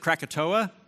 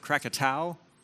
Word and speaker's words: Krakatoa: On August 26th, 1883, a volcano erupted on the Indonesian island of, depending on how you pronounce, some people pronounce it Krakatoa, some people pronounce it Krakatau, but the volcano exploded on this island, and the blast from the eruption was estimated at 310 decibels.